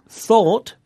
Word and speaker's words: thought: Now, the words thought, spelt T-H-O-U-G-H-T, and ought, spelt O-U-G-H-T, both have the O-U-G-H pattern in them, and it's pronounced aw. Thought. Ought. – Thought. Ought. – Mm. – And there are more O-U-G-H verbs with the same pronunciation, like fought, the past of fight, and bought, the past of buy.